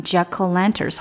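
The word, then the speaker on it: jack-o'-lanterns: 大家看到这个字的写法是中间有一个 o，但这个 o 呢，它其实发了一个呃的音，所以你把它连读起来就变成 jack o' lanterns，好像 o 没有发音一样。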